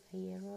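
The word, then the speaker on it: Iero: según él, pero según lo italiano no se pronuncia así, pero da lo mismo. Él dice que es Aiero, así que será.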